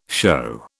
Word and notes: shew: British English

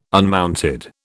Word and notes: unmounted: British English

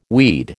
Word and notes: weed: American English

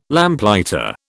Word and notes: Lamplighter: British English

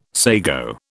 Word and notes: sago: British English

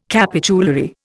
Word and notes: capitulary: Indian

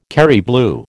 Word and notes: Kerry blue: American English

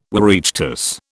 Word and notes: Oireachtas: British English

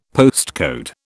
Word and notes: postcode: British English

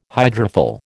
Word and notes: hydrophil: American English

